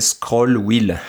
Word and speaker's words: Squirrel: Mais ce qui est marrant, c'est que quand on va sur la page GitHub du développeur, il dit que squirrel, ça devrait se prononcer scroll wheel.